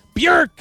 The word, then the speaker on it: Björk: Yes, that is Björk. Björk, that's how you say it.